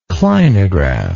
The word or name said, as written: clinograph